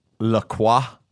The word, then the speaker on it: La Croix: Do you know how to pronounce it? – La Croix or something like that. – La Croix. – Yeah, La Croix. – That's right. That's right. And you should say it that way.